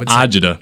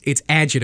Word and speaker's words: agita: I thought it was agita. – It's not. It's agita. It's spelled A-G-I-T-A, but it's pronounced agita if you're from the island.